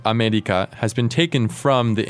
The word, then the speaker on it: アメリカ: It's not America, but it is... – America. America. – So it's sort of somewhere in between a tapped R and an L.